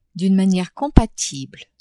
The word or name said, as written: d'une maniere compatible